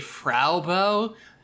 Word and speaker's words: Frau Bo: It's spelled Frau Bo, but it's pronounced consistently Frau Bo, like the German word for a lady.